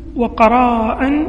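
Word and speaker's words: قَراء: وأصل تقري الضيف من قريت الضيف قرا، وقراء من قريت الضيف قرا وقراء، وقرا بكسر القاف، وقراء بالقصر بالمد بفتح القاف والمد، قراء يعني تنطق قرا بكسر القاف والقصر، وقراء بفتح القاف والمد، تقول قريت الضيف وأقريه.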